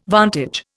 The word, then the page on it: vantage: Indian